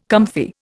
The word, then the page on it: comfy: Indian